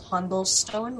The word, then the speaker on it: Hundelstone: So, I was raised in a town called Hundelstone, which is up north. – Humblestone?